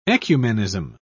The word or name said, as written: ecumenism